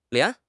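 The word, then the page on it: lian2: tone 2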